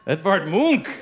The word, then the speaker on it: Edvard Munch: Edvard Munch! Extra yes. Credit for the pronunciation, yes.